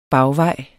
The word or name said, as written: bagvej